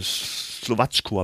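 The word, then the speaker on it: Slovácko: Slowatschko, habe ich gehört, wird es ausgesprochen, keine mhm.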